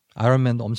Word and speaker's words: 아이언맨: 아이 언, 언. 언어 할 때 언. 어. 맨. – 어, 어. 애기처럼 말하는 사람. 어. 그게 아이언 아이언맨.